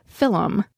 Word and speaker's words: film: To us Irish, a film is pronounced film. The Irish name colm, C-O-L-M, has two syllables, colm.